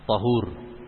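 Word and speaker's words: ṭahūr: Ta tahur. Kemudian terkadang ta didhammahkan. Bacanya gimana? Hah? Ta difatahkan tahur, ta didhammahkan tuhur.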